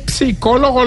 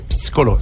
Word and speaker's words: psicólogo: Eso sí, es una depresión, hermano, que ahí el psicólogo lo mandó a escuchar. no se dice psicólogo, psicólogo, no, la P no suena.